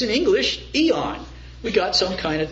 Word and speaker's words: aeon: It's pronounced eon in the English language.